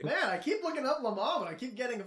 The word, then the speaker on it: Le Mans: L-E-M-A-N-S. Le Mans.